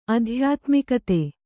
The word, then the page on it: ಆಧ್ಯಾತ್ಮಿಕತೆ: ādhyātmikate